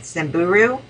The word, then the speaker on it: Samburu: Um, I went to Kenya, and um, the first place we stopped was um, Sim- uh, uh, Samburu. – Did I say that right? Yes.